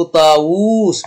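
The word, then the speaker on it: طاوس: وطاووس كذلك، النطق طاووس بواوين، لكن في الخط تحذف الواو الأخيرة وهو سماها في الوسط.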